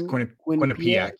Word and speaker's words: Quinnipiac: Quinnipiac. Yep. Quinnipiac.